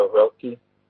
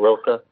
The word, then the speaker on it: Rilke: Also, there's an amazing book that was given to me called Letters to a Young Poet by Rina Maria Rilke, or Rilke, depends how you pronounce it, which really kind of focused me on what I wanted to do with my life.